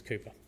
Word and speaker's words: Cowper: It's spelt Cowper, but trust me, go with me, it's Cowper.